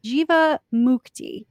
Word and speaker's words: Jivamukti: Okay, I said it wrong, and I was like, dang it, I said it wrong, so I repeated it. Jivamukti.